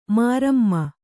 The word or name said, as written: ಮರುಮೈ